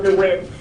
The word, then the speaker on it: DeWitt: My name is Michelle DeWitt. My last name is spelled D-E-W-I-T-T. Mm-hmm.